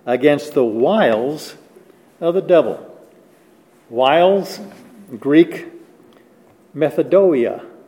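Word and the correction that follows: Methodia (μεθοδεία): Methodia. Got an extra syllable in there. Methodia.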